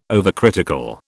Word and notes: overcritical: British English

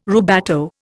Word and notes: rubato: Indian

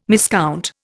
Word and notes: miscount: Indian

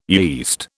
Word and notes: yeast: British English

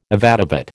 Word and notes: avadavat: American English

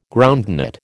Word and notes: groundnut: American English

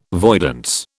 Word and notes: voidance: British English

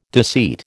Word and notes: deceit: American English

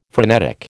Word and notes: frenetic: American English

